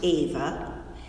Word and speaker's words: Eva: The teacher also learned that E-V-A was pronounced Ava, and that you didn't pronounce the G in Montanese.